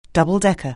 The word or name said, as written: double-decker